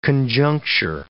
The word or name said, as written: conjuncture